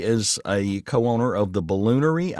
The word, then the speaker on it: Balloonairy: And you did you did say that correctly. It's the balloonairy. It's a little play on distillery, kind of.